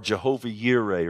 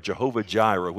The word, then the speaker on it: Jehovah Jireh: You see, the providence of God, the Lord will provide, or Jehovah Yireh, or Jehovah Jireh, whichever pronunciation you desire.